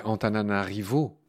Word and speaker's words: Antananarivo: On va redire le nom de cette capitale, c'est Antananarivo. – Hein. c'est comme ça qu'on prononce, euh, Antananarivo.